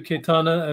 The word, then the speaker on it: Cayetana: Pleasure to meet you, Cayetana, and be with you, answer your questions today.